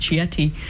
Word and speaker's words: Chieti: This is the Archdiocese of, I think it's pronounced Chieti. – Yeah.